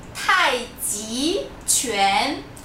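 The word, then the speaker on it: Tai Chi Chuan (太极拳): Tai Chi Chuan. É T-A-I. – Tai Chi Chuan. – J-I. A segunda sílaba não é C-H-I, e sim J-I. E a terceira sílaba é Q-U-A-N, não é C-H-U-A-N.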